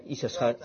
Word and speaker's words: Issachar: Issachar, zo spreek je het uit.